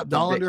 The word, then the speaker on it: Dollander: Yeah, there's Chase is it Chase Dollander. There is that how you pronounce it? – The, yeah, Dollander the, from Tennessee.